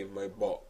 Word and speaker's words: Maybach: It's a damn shame it's irking me every time you say my balk. – Yeah, because that's how you say it. – I know, but that's why I'm mad, because that's how it sounds.